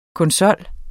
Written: konsol

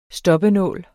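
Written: STOPPENÅL

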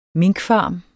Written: minkfarm

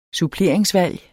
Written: suppleringsvalg

